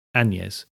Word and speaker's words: Agnes: Both names were common in the area at the time, but Anne is otherwise referred to as Agnes, spelt A-N-N-E-S, and pronounced Agnes, with a hint of a non-existent G.